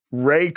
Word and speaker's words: Wray: Ray Carlton, but it's spelled W-R-A-Y.